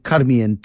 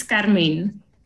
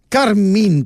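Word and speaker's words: Carmién: Is it Carmien or Carmine? – It's Carmine. – Carmine, because it's got a little like-y thing on the E, and that just confused the living daylights out of me.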